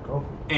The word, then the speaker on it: Kofi: Oh, maybe that's how they pronounce coffee in Ghana. – It's Kofi.